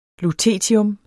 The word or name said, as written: LUTETIUM